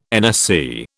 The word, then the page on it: NSC: British English